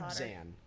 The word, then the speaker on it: Abzan: Azban. – Someone's been reading too much Harry Abzan.